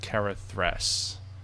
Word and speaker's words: Karathress: Carath Karathress.